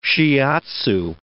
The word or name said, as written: shiatzu